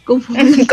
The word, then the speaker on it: confabulación: Pero mientras tanto anda ahí una, confu una rara, confulación, ¿Ay, ¿cómo se dice? Confabulación. confabulación. Porque andan unos monstruitos por ahí.